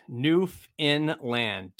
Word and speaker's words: Newfoundland: Newfoundland. Don't pronounce found.